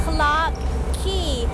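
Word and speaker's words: Clarke Quay: Clark Quay สะกดหน้าตาแปลกๆนิดนึงนะ QUAY แต่คำนี้อ่านว่า Key เหมือนคีย์กุญแจแล้วค่ะ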